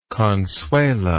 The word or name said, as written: consuela